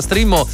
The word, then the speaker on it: Streammo: che si pronuncia Streamo ma si scrive Streammo.